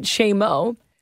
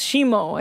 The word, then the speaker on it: scemo: So during your concoction segment, when Roz called it Shamo, it had me dying because in Italian, it translates to stupid or dummy. – Oh, my God. – Oh. – Shemo is pronounced Shemo, I think. She wrote S-C-E-M-O is pronounced S-H-E-M-O.